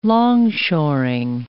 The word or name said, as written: longshoring